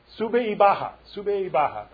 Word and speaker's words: sube y baja: But I always keep that idea of the seesaw, the sube y bajo. How do you say it? Sube y baja.